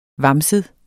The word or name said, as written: vamset